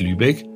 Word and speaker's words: Lübeck: Und deswegen wird Lübeck lang ausgesprochen, das E.